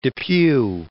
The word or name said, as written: Depew